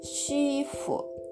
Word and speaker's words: 师傅: なので、えっ、ー、と最初の音「し」に軽く添えるような感じで、えー、発音してみてくださいそしてこの SH は反り舌音ですので口の中で舌を上に反り上げてその左右から息を漏らすようにして「し」と発音しますこれで「シーフ」「シーフ」と言っ